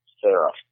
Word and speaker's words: Ferro: Am I saying that right, Ferro? – Yeah, Ferro.